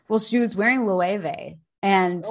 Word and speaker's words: Loewe: No, it's Loewe. It's spelled L-O-E-W-E.